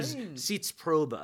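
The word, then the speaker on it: Sitzprobe: Or Zitzprobe, I think, is the proper pronunciation. – It's actually German. – It is German. It's a German term.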